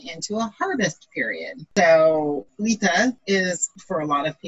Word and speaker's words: Litha: Litha. Sorry, no T-H. Litha. L-I-T-H-A.